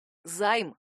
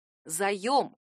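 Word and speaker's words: заём: Жертвой такой экономии регулярно становится слово «заем», которое многие пишут и произносят по-другому — «займ». Один слог — коротко и ясно. Но на самом деле это ошибка. Правильная форма именительного падежа только одна — «заем».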